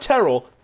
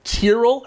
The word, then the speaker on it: Tyrrell: Not Terrell, Tyrrell Hatton? Anyone who pronounces his name like that, I don't trust.